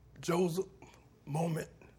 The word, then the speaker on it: Joseph Moment: Joseph Moment Jr. J-O-S-E-P-H M-O-M-E-N-T Jr. Okay.